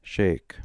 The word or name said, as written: sheik